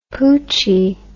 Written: poochy

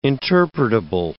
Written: interpretable

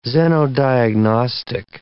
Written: xenodiagnostic

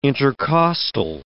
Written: intercostal

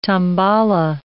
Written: tambala

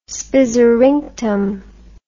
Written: spizzerinktum

